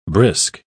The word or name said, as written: brisk